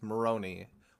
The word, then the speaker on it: Moroni: Moroni was the, as I was watching YouTube, it was Moroni, widely pronounced that way.